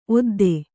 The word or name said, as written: ಓದ್ದಿ